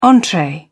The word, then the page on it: entree: British English